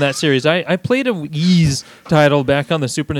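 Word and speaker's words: Ys: How do you fucking pronounce this? Wise 6? The Ark of... – It's Ease.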